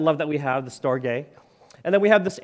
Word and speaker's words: storge: And then another one is pronounced storge, at least I pronounce it storge.